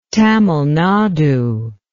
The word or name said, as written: Tamil Nadu